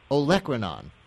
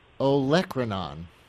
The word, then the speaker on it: Olecranon: I pronounced this one wrong, by the way, in promoting the segment. I won't give my incorrect pronouncer again. I'll just give you the right one. Olecranon. – Could you repeat that, please? – It's Olecranon.